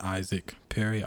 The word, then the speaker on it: Isaac Perry: You can reach me on Twitter at Isaac Perry, ISAACPERRY.